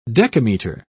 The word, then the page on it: decameter: British English